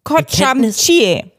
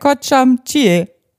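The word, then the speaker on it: kocham cię: Ich koch am ICE. So kann ich mir das jetzt merken. Kocham Chie. – Ja, so ungefähr, glaube ich.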